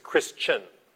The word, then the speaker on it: Kristiaan: My name is not Chris, it's Christian. It's not spelled C-H-R-I-S-T-A-N. It's spelled K-R-I-S-T-I-A-A-N.